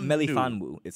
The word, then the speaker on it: Melifonwu: Ifeatu Melifonu. – Melifonwu. So it's Melifon. Yeah, so it's wa- Ifeatu yeah, yeah, Anyways, you'll see him today.